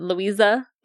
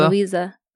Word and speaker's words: Louisa: I guess it's Louisa. Is it Louisa? – That's how I would say it. – Louisa. I would say with a z. – With a z. – Louisa.